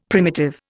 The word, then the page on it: primitive: Indian